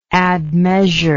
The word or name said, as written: admeasurer